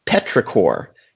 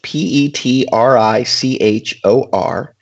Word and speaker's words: petrichor: It's pronounced petrichor, and it's P E T R I C H O R, and it's the pleasant earthly smell after rain.